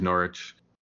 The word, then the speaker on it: Norwich: They're in the east of England in the city of Norwich. If you look up Norwich on your computer or phone or whatever, it's spelled N-O-R-W-I-C-H. Basically, the W is silent.